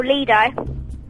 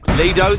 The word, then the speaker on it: Lido: And failing that, there's always your local Lido. – Lido, the correct pronunciation, well done you.